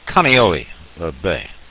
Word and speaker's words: Kaneohe Bay: That's Kaneohe Bay is the way that that's pronounced.